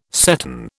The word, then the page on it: seton: British English